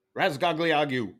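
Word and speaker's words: Razgatlıoğlu: How do you say that last name? – Razgagliagu. – That's pretty good, actually.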